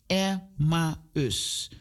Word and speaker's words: Emmaus: Zeg het even na. Emmaus. Dus het is drie lettergrepen, jongens en meisjes.